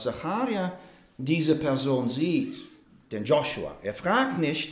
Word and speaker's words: Joshua: In manchen Übersetzungen steht Jeschua oder Josua, aber die richtige Aussprache ist Joshua.